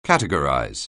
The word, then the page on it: categorize: anglais